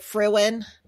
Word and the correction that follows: Fermin: Fermin, yeah, Fermin.